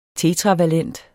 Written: tetravalent

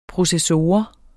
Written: processor

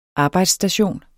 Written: arbejdsstation

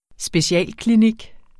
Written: specialklinik

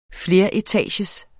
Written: fleretagers

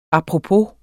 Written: apropos

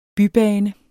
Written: BYBANEN

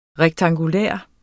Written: rektangulær

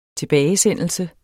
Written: tilbagesendelse

